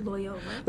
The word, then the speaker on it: Loyola: She graduated Texas A&M in 1999 with a political science degree and continued her law career, continued in law school at La Jolla. Did I say that right? – Loyola. – Loyola University in New Orleans.